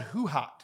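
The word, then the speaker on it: Hu Hot: It's called Hoo-Hot. Have you ever heard of Hoo-Hot? – Stop. – I'm serious. – Is this real? – Yeah, it's real. – Spell it. – It's H-U space H-O-T.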